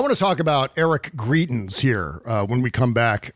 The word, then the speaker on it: Greitens: You say Greitens, I say Greitens. – Greitens. I'm going to go with your... – Yeah. And he says Troth Central. – Troth Central. – Right. I'm going to go with your pronunciation, which is Greitens. We're going to go with Greitens on the show.